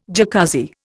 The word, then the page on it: Jacuzzi: Indian